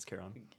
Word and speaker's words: Charon: Charon. Or Charon. – Charon? – Yeah, Charon, sorry. It's Charon.